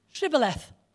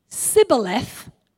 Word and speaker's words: Shibboleth: Shibboleth. If the person replied Sibboleth, because he could not pronounce the word correctly, they seized him and killed him at the fords of Jordan.